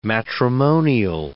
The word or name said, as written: matrimonial